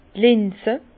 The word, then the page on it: d' Linze: Bas Rhin; Herrlisheim